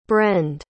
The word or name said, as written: brend